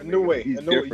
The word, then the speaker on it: Inoue: My Inoue, you said it correctly. Inoue.